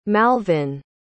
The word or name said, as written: malvin